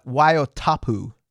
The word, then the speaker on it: Waiotapu: Waiotapu. – Oh, that's near Rotorua. – Yes. – You actually didn't do too badly. I think you would pronounce it Waiotapu. – Waiotapu.